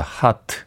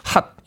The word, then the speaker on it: H.O.T.: 하트. 핫 이렇게 발음해야죠. H.O.T. 핫.